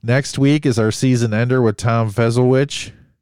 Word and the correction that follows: Fesolowich: Am I saying that right? Is it Fesolowich? – Yep, there it is. – Fesolowich, big bat.